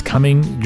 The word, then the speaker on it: Cumming: That was my good buddy, Woody Brown, of the Rhodes Group, and I believe I pronounced it wrong last time. Sorry, Woody. I think it's Cumming, Georgia. Yes, I think I got it right that time.